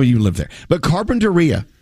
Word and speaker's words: Carpinteria: If you're from California, you know that little town is actually pronounced Carpinteria.